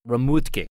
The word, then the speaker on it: Remhoogte: The winery is spelled R-E-M-H-O-O-G-T-E, but it's pronounced Remutke.